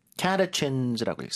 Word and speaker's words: catechins: Catechins라고 읽습니다. 영어에서는요. 발음 다시 한 번요. – Catechins. – 앞에 힘주고 catechins.